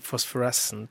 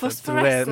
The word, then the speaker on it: Phosphorescent: Phosphorent, er det riktig uttalt? – jeg vet ikke. det Phosphorescent. Eh, Phosphorescent.